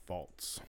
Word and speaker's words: Pfalz: Ah, the Pfalz.